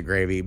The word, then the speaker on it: Grxxy: And then the second verse is Gravy. But I would pronounce it Gravy. But it's spelled G-R-X-X-Y. But I think that's how the kids would spell Gravy.